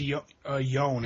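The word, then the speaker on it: Ioan: I think it's actually pronounced Yone, actually.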